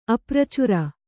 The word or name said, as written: ಅಪ್ರಚುರ